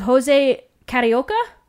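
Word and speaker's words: José Carioca: It was also the introduction of Jose Carioca.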